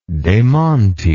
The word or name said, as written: dementi